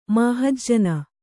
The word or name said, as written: ಮಾಹಜ್ಜನ